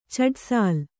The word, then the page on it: ಛಡಸಾಲ್: chaḍasāl